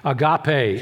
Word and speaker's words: agape: He uses the third word that's, that's most, most well known, agape, is the Greek pronunciation.